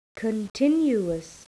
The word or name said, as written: continuous